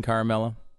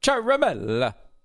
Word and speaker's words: Ciaramella: A lot of people are saying Ciaramella. It's Ciaramella. – Ciaramella. – Ciaramella. Because it's Italian. – Yeah, okay. – And the emphasis should be on the third syllable. So Ciaramella.